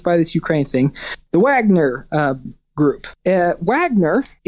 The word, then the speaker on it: Wagner: And he called it the Wagner Group after the name of a composer by the last name Wagner, but we would pronounce it Wagner because the man is a German composer.